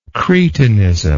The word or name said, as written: cretinism